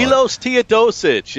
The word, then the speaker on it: Milos Teodosic: Milos Teodosic is the guy.